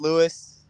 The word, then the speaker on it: Luis: Yeah, so a lot of people do ask me, in fact, is it Luis, Luis? It is spelled, it's Luis. That's how we pronounce it.